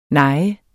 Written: nej

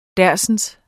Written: dersens

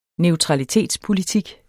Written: NEUTRALITETSPOLITIK

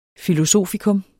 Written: filosofikum

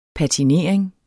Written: patinering

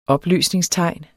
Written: opløsningstegn

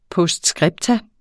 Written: POSTSCRIPTA

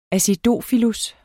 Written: acidofilus

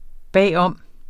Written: bag om

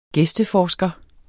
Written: gæsteforsker

